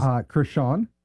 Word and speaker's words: Creshawn: Krishan. – And spell that for me. – C-R-E-S-H-A-W-N. It's like Chris and Sean together. – S-H-A-W-N? – Yes, sir.